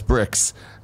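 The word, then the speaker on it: Brix: Nanobiologist says, bricks is pronounced as bricks and is the sugar content of an aqueous solution.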